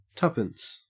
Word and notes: tuppence: Australian English

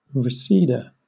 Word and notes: reseda: British English

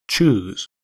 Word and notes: chews: American English

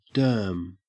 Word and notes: derm: Australian English

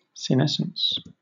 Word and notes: senescence: British English

